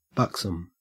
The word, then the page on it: buxom: Australian English